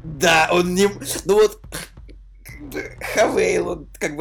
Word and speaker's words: Haval: H-A-V-A-L. Вот она пишется как «Хавал», «Хавал», но почему-то она произносится как «Хавейл».